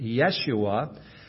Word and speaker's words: Yeshua: Remember Jesus' name in Hebrew is Joshua. Sometimes we pronounce it Yeshua.